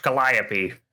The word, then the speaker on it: Calliope: I think it's pronounced Calliope. – Creepy Calliope. – That sounds way more correct than what I said.